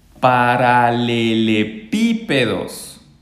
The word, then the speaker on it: paralelepípedos: Y aquí paralelepípedos. Ahí tiene el acento en la I.